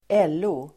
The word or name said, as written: LO